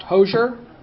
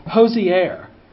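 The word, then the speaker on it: Hozier: And actually, the guy, do you know his name is not pronounced Hosier? It's Hosier, which makes me like him even less.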